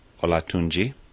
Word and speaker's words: Olatunji: So here we're going to have, I think the name is pronounced Olatunji from Nigeria.